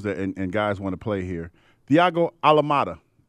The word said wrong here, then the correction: Almada: Yeah, Almada is one of the most exciting young players out of Argentina.